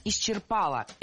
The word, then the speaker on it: исчерпала: Было сказано «исчерпала», и это правильно. Почему же я решила поговорить об этом слове? Ну, во-первых, это как раз тот самый пример, когда ударение не падает на окончание. Но его туда и не хочется ставить. Во-вторых, таким ударение в этом слове стало недавно. Раньше был в ходу вариант «исчерпала», потом появилась двойная норма – исчерпала и исчерпала. А сейчас вариант «исчерпала» окончательно вытеснил альтернативный.